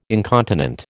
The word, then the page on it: incontinent: American English